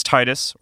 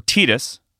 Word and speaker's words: Tidus: Number 7 is Tidus, or Tidus, depending on how you want to pronounce it, from Final Fantasy 10.